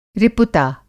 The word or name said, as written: रिपुता